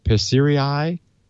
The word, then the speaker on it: Persirai: and I don't know how to pronounce this, Perisirai, P-E-R-S-I-R-A-I. – Persiria? – It ends in an R-A-I. Persirai? Persiri, I don't know how to pronounce it. – Persirai. – Good.